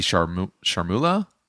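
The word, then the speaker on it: chermoula: And that spicy charmoula, is that how you pronounce it? – Yeah, yeah.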